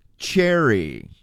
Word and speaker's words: chary: I mean, it sounds like it's spelled different. – It's spelled differently. – Fruit? – That's correct. – Okay, Neil Diamond had a song. – Did he? – Cherry. – Yes. – Yeah. – I don't think it was about this word, though. – No. – Cherry, this spelled C-H-A-R-Y. – Oh. – Little different, no E. Oh. Little different. It's an adjective. It means wary or cautious. – Just say wary. Instead of wary, say cherry.